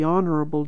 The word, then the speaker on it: Honorable: She was always called the Honorable Jane, most of the men sounding the H and pronouncing the title as 4 syllables.